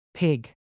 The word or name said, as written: pig